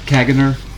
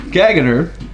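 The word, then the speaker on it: caganer: I don't know if you saw, is it Kaganer? Is how you pronounce it? – Yeah, that's the... – Kaganer from Catalonia.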